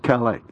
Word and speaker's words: Calais: Folks, it's pronounced Calais.